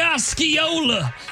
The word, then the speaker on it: Osceola: John Hamm married his Mad Men co-star, Anna Oshiola. I don't know how to say this. Oshiola? – It's obviously Osceola.